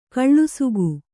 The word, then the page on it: ಕೆಲಸಿಗ: kannada